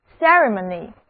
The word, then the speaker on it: ceremony: 那最后一个关于 MON 这个词根的单词是典礼仪式 ceremony， ceremony 它的拼写是这样的 C-E-R-E-M-O-N-Y，第一个字母 E 要读作小口的 E，而第二个字母 E 则读作短的 E。单词的前半部分 C-E-R-E 是 C-E-R-T 的变体，表示确信，而词根 M-O-N 是提醒的意思，字母 Y 是名词后缀。